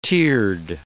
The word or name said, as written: tiered